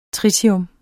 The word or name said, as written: tritium